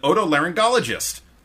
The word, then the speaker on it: otolaryngologist: She says, I'm currently in the waiting room of an otolaryngologist.